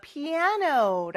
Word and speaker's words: piano: 같은 영어 표현이긴 한데 발음이 조금 달라요. I play the piano, piano. 이렇게 두 번째 음절에 강세가 있는 거 잘 기억해 주세요.